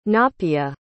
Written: napea

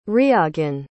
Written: Riagan

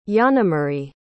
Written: yanamarie

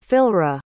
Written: Philyra

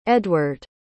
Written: eadward

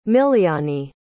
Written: miliani

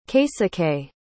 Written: keisuke

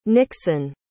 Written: nikson

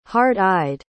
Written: hard-eyed

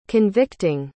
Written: convicting

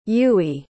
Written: Yui

